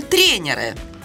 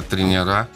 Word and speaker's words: тренера: Как говорят учителя физкультуры или тренеры? – Во-первых, ты тренеры, а тренера. – Тренера. Да, именно с таким ударением все спортсмены, все физкультурники это слово произносят. Тренера.